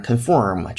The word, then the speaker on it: conform: 好，再接下来，be insulated to conform to new building regulations，那又出现了 conform to，当然 conform 这种发音是美音，conform 这是更倾向于英音，然后表示啊遵从于。